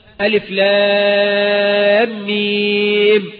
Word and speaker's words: الم: هات لي البقرة هتلاقيها أولها ألف لام ميم مكتوبة إيه؟ ألم، مكتوبة ألم. أنت ما تقطعش بمسماه، ما قلتش ألم، نطقت كل حرف باسمه.